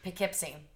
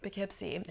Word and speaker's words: Poughkeepsie: Poughkeepsie. – Poughkeepsie. Yeah. It doesn't look like Poughkeepsie. – I know, right? – It looks like Pog something. I don't know. Whatever. Poughkeepsie.